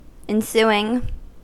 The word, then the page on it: ensuing: American English